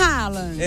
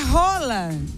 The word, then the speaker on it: Haaland: Não é Haaland. – É, me ensina. – Tá falando errado. – Me ensina. – É Roland.